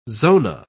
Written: zona

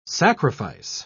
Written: sacrifice

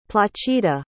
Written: placida

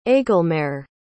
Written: aegelmaere

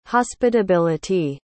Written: hospitability

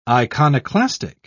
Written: iconoclastic

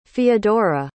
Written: feodora

